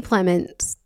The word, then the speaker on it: Plemons: Jesse Plemons, that's how you say his name, right? – Yes.